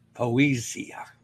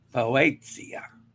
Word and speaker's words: Poesia: All right, and finally this morning, folks, MSC Poesia. I didn't say that right. Poesia is starting MSC Cruises 2024 World Cruise today.